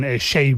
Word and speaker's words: Seyi: Pasa, por ejemplo, con Shay Vibes, ¿no? Que se escribe Seiyi, S-E-Y-I.